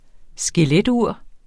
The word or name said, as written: skeletur